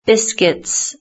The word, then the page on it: biscuits: British English